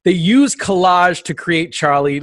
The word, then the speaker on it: Charlie: And we are saying it correctly. It doesn't have an R. We should say that. The mascot, the skull with the bat wings, his name is Charlie.